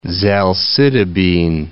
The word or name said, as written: zalcitabine